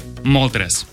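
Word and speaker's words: Moltres: проверил ударение, вроде так. Артикуно, Заптос и Молтрес.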